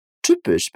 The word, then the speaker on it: Typisch: Typisch precisa ser pronunciado com certo cuidado, beleza? pois o Y, né, após o T, ele tem um som de U.